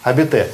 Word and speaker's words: habiter: Habiter. И здесь мы имеем в виду, что вот эта первая буква этого слова не читается никогда.